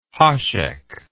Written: hasek

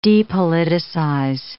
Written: depoliticize

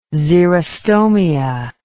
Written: xerostomia

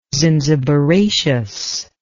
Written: zinziberaceous

